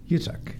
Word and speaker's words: Yitzhak: Yitzhak.